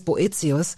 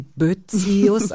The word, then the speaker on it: Boetius: Dieses Boetius, bevor ich es besser wusste, habe ich sie Boetius ausgesprochen. Ja. Sie haben diese kleinen seltenen Punkte über dem E, wenn man will, oft fallen sie weg.